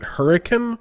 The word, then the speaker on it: Hurricane: But for some reason, they pronounce it Hurricane. – Hurricane. – I'm not sure why. I guess it's a Utah thing, probably.